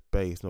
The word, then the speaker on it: Buys: I heard her name being pronounced as Bays, not Bars, because her name's her name her surname's being pronounced or spelled B U Y S as if buys if you're buying something.